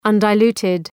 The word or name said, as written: undiluted